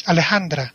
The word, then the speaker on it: Alejandra: Alec or Alejandra or Alec. How do you say that name? – His name is Alejandra.